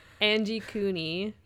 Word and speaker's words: Angikuni: Angikuni Lake. I'm pretty sure that's how you say it. – That sounds right to me.